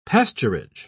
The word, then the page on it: pasturage: British English